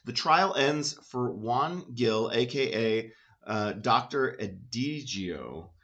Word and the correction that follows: Egidio: uh, found, no, I said that wrong, Egidio, uh, founder of a little Protestant community at Seville.